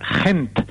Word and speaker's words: Gent: Y si hay que pronunciarlo, teniendo en cuenta que es una ciudad flamenca y que en Flandes lo que se habla es el neerlandés, habría que decir Gent.